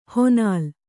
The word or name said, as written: ಹೊನಲ್